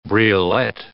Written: briolette

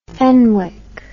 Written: fenwick

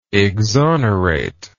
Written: exonerator

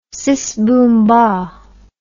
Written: sis boom bah

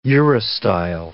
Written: urostyle

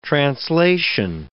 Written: translation

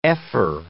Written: ephor

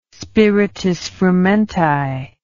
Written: spiritus frumenti

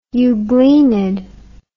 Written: euglenid

